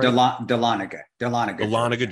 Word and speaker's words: Dahlonega: Dahlonega, Georgia.